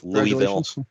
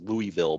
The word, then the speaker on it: Louisville: For anybody that doesn't know, University of Louisville, for anybody that does not live in the Kentucky, like tri-state area, whatever, it's Louisville. Yeah. Probably pronounced Louisville by anybody outside of this area.